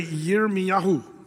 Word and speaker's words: Yirmiyahu: The Hebrews, by the way, they pronounce Jeremiah, we say Jeremiah, you know, but it's actually the Jews say Yirmiyahu. That's how they'd say his name.